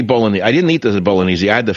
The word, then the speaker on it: bolognese: So she got the bolognese, right? The silent G. People say bolognese.